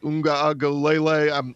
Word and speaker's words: Uiagalelei: You know, Clemson is loaded with another great quarterback in DJ Uiagalelei. I've got to work on the pronunciation, but I think you nailed it.